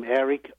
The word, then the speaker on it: Eric: I mean, if people go on the internet, they just look for a child al confino, or maybe just my name, Eric Lamet. – And that's spelled L. – They'll find the book all over the place. – That's right. And Eric's name is spelled with a C, E-R-I-C. And yep. – Lamet is spelled L-A-M-E-T.